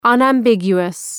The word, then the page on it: unambiguous: ,ʌnæm’bıgju:wəs